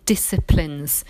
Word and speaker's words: discipline: Um, but he certainly left a lasting impression on many disciplines. Discipline, we've talked about this word, it's got a silent C in it, it's got two meanings.